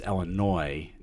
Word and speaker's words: Illinois: And so a quick side note, because I know you're from Michigan, it's Illinois, not Illinois. – Oh. – The S is silent.